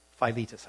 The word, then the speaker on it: Philetus: Among them are Hymenaeus and Philetus. Philetus, I believe, is actually the proper pronunciation.